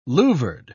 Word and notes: louvered: British English

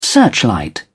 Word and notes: searchlight: British English

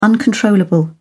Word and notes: uncontrollable: British English

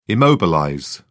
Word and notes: immobilize: British English

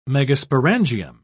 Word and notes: megasporangium: British English

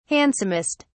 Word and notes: handsomest: British English

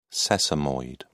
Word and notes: sesamoid: British English

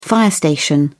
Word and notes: fire station: British English

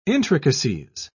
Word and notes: intricacies: British English

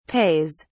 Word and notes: pays: British English

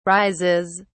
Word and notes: rises: British English

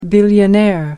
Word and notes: billionaire: American English

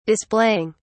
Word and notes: displaying: British English

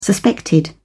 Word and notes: suspected: British English

